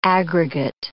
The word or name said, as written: aggregate